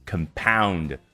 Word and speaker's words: compound: 那这儿呢，很显然是一个动词，它的发音 compound，好像重音放在末尾更方便去发力一样的，更有一种动词的感觉。